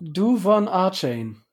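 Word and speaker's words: De'Von Achane: Adrian Franke schreibt auf Twitter, wenn Devon, weißt du genau, wie er ausgesprochen wird? – Duvon Achane.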